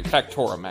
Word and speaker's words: Factorum: Can I roll a memory check to remember who Factoria is? – He worked for us. – Hey, Factorum, actually. I'm sorry, I said his name wrong.